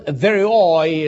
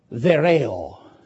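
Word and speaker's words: theorei: The word pronounced theroi is spelled T as Tom, H E O R E I. Thereo.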